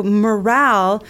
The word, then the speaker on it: morale: So morale, this looks very similar to the word moral, mm-hmm. which we have talked about today. Mm-hmm. But it's spelled M-O-R-A-L. E at the end, and it's morale.